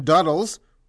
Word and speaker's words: Duddles: Cops say 47-year-old Benjamin Duddles, rhymes with cuddles,